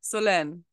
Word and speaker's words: Solène: None of the above. Solène.